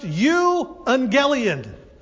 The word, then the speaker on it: euangelion: It looks like the word evangelist, doesn't it? But it's pronounced euangelion.